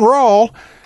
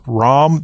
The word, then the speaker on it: Rahm: And Brian, because he likes to cause problems, he would yell out, "John Rahl," whenever John Rahm would be hitting. And occasionally we'd get a stare like, does this guy not know how to pronounce Rahm, the Spaniard's name?